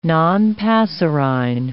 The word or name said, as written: nonpasserine